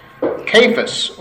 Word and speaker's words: Cephas: That is the actual Aramaic name that Jesus would have called him, and it's actually pronounced Cephas, or maybe Kepha, because the S is added as a convention by Greek to male names at times.